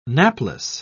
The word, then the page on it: napless: British English